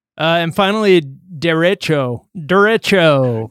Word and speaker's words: derecho: But they call it a derecho, according to the pronunciation guide on CNN, so you can really sound like a person who doesn't know where the origin of that word is.